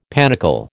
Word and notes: panicle: American English

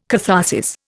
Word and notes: catharsis: Indian

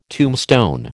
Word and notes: tombstone: American English

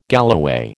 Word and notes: galloway: American English

